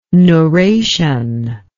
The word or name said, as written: neuration